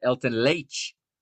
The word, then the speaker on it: Leite: And shout out to my new friend there, my new friend Nino Torres, pronouncing it Elton Leite. He's got the Brazilian pronunciation there.